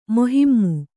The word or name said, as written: ಮೋಹೀಮ್